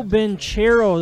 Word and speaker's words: Banchero: Paolo Banchero, the power forward out of Duke. – Come on, man. – Number four. – Banchero. – Oh, sorry. I've heard about that place. – Silent H.